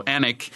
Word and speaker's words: Alnwick: Alnwick, it's one of those English shibboleth words that's not spelled anything the way it's pronounced. There's an L and a W in there somewhere, but it's not pronounced.